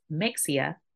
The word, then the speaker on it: Mexia: And Mexia is spelled M-E-X-I-A, not pronounced Mexia, but Mexia, Texas.